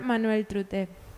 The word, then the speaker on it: Manuel Trute: con el profesor Manuel Trute. Mucho gusto. Lo dije bien, ¿verdad? Perfecto.